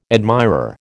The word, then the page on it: admirer: American English